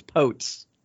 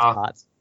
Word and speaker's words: Potts: I was like, I wonder if it's Potes. Nope, it's Potts.